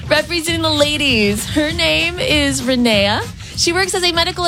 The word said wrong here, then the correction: Renee: Am I saying your name correctly? – It's Renee. – Oh, I'm so sorry. Let's hear from Renee.